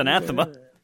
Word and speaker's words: anathema: I know this is like this anathema to you, or however you pronounce the word, but I think it's anathema. – Anathema. There we go.